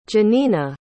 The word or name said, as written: jannina